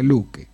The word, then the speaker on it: Loke: En los idiomas escandinavos continentales, sueco, noruego y danés, su nombre es Loke, o se pronuncia Luke.